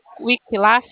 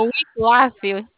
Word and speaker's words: Wiklaf: Olá, eu falo com Wikilaf, é assim que se pronuncia? – Eu não sei, como é o nome? – É o Wiklaf.